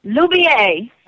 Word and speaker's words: Loubier: How do I pronounce Daniel's last name? – Loubier. – That's what I thought. Okay. We also want to say that. – French. – Yes, Loubier.